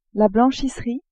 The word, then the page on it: blanchisserie: female